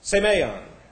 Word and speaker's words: semeion: It's S-E, and then we have M-E-I-O-N. This is S-E. You have an 8 in your class notes there. That's to force you to say that eta, long A. M-E-I-O-N. Semeion.